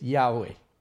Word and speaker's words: Yahweh: We pronounce it these days Yahweh, because we just need the vowels.